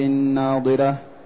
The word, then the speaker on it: ناضرة: ثم قال تعالى وجوه يومئذ ناضرة، من النضارة أي حسنة بهية مشرقة مسرورة، التي هي النضارة والجمال والبهاء والحسن، وهي تنطق بالضاد، والأخرى تنطق بالضاء التي من النظر.